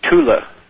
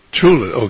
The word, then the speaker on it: Thule: So Joseph was telling us about the Thule Society, or how do you exactly pronounce that? – It's a hard T, Thule. – Thule, okay, go right ahead.